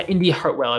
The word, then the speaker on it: Indy Hartwell: It was something about someone getting pulled from, I'm trying to remember what happened, but basically the person, I think it was Indy Cartwell. Am I saying her name right? Yeah, Indy Hartwell, I believe Indy Hartwell.